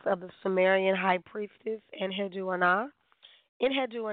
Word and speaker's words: Enheduanna: just trying to figure out how to pronounce her name. And it just kind of came to me, Enheddu Ana, Enheddu Ana. So I'm going to call her that. It's spelled Enheduanna. And, of course, you can hear Inanna in it. She was a high priestess of Inanna. However, her name was Enheddu Ana.